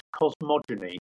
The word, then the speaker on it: cosmogony: You use the word cosmogony. Could you explain that for folks? – Cosmogony is a hard G.